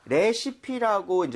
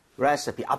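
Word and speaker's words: recipe: 익숙한데 우리가 레시피라고 이제 외래어 표기는 하지만 실제로 발음할 때는 레서피에 가깝게 발음을 좀 했으면 좋겠거든요. 오케이. 자, 여러분들도 같이 한번 해볼게요. 레시피보다는 레시피. 레시피. 앞쪽에 강세를 주고 서에 가깝게 갑니다.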